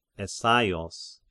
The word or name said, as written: Essaios